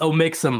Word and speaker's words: Omixim: All right, next up, we have Amixim joining the show. What's up, man? How you doing? – Hello. I'm doing good. It's pronounced Omixim, by the way. It's a hard O.